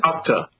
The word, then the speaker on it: Okta: Okta.